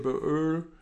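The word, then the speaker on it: Øl: Nee, dieses O mit Querstrich durch und dann L. Okay. Ausgesprochen Öl.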